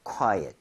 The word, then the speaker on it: quiet: U의 강세를 좀 찍어서 읽을 때 거기 강세 주면요. ot보다도 at에 가깝게 오히려 네. 발음이 될 겁니다. – Quiet. – So 약간 quiet, quiet 이런 느낌으로. 그러니까 강세를 주는 게 그렇게 중요해요.